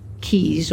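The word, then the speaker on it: quay: Now that I'm thinking about it, I think it is key.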